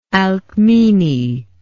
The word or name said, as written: alkmene